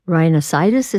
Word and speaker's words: rhinosinusitis: I mean, you have esophageal illnesses, you have GERD, as we mentioned, you have rhinosinusitis, is that how you say it? – Yeah, painful.